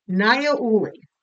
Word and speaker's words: Niaouli: Niaouli.